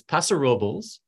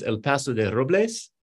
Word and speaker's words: Paso Robles: So you had it almost right. It's Paso Robles. Though the full name of the town and like its original Spanish is El Paso de Robles, but the locals say Paso Robles. Took me years to learn to mispronounce it correctly.